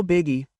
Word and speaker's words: biggie: No biggie, no biggie. – No biggie. No biggie. It's short for how it's not a big problem. No biggie. Here, beggy. Beggy. Emphasizing the first syllable.